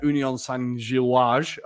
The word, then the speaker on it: Union Saint-Gilloise: Union Saint-Gilloise, I've been informed is how you say it, had 7 shots and 4.